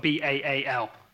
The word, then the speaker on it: Baal: And he'd, uh, he turned away from God and he'd started to, uh, to worship uh, Baal, uh, Baal, Baal, we'll call him Baal. It's spelled B A A L. So we're going to say Baal.